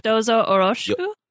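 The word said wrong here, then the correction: Dozo yoroshiku (どうぞよろしく): Dozo yoroshiku. So Y-O-R-O-S-H-I-K-U.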